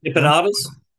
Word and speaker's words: empanadas: Correct me if I'm saying this wrong. Empadas. Em- empanadas. Okay. Yeah.